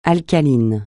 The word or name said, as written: alcalin